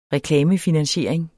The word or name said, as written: reklamefinansiering